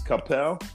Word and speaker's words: Coppell: I've been saying it wrong for years, and it's Coppell.